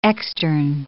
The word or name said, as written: externe